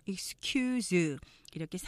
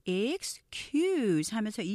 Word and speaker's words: excuse: 첫 번째, 우리가 많이 쓰지만 보통 excuse 이렇게 사음절로 아, 익숙하게 발음하시는 분들이 많은데 아, 음절 보시면 excuse 하면서 이음절로 하시면서 뒤에 강조하셔야 돼요. excuse me. 이렇게 되죠.